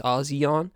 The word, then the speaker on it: ASEAN: It looks like Asian with an H, but it's ASEAN.